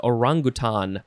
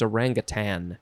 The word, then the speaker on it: orangutan: It's also International Orangutan Day, but I believe the proper way to say it is orangutan. Orangutan? There's no G at the end, so I think it's orangutan.